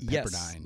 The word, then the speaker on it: Pepperdine: She is a associate economic professor at Pepperdine. Did I say that correctly? – You got it. Associate professor of economics at Pepperdine University.